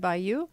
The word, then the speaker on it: Bayot: First name is S-A-N-D-I-J-A. So S-A-N-D-I-J-A. Last name Bayou, B-A-Y-O-T. Again, French B-A-Y-O-T.